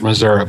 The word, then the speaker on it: Missouri: You know, not all Missourians pronounce it that way. I think the people toward Kansas City and St. Louis, which are on both ends, tend to look down upon us. the hillbillies that pronounce it Missouri, but we don't care.